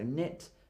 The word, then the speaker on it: knit: And my last one today, when the letter K is before the letter N, the letter K is normally silent. So knit, knock, know, and knowledge. Usually a K before an N is silent.